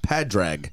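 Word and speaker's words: Pádraig: Padrag, as it's spelled phonetically, the Gaelic name for Patrick.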